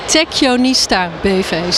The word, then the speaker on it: Techionista: Techionista BV, zo spreek je het uit.